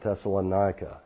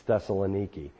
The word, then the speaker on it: Thessaloniki: Thessaloniki, or as American Christians mispronounce it, Thessalonica. It's Thessaloniki. We overnighted there,